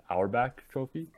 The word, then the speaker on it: Auerbach: I hope I'm pronouncing this Auerbach Trophy.